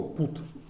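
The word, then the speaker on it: put: Например, слово там «бат», да, оно пишется «би-юти», читается «бат». А точно так же пишется слово «пут», «пи-юти». Это «бат», а это «пут». И почему это читается «бат», а это «пут», никто объяснить не может, понимаете?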